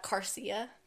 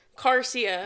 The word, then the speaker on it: Garcia: Um, I mispronounced uh, Garcia. How did you say it? Uh, Garcia. Carcia. Carcia. I didn't see the thing on the G, and so I said Carcia.